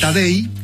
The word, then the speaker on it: Tadej: Tadej, como Tadeo, pero Tadej Pogačar se escribe.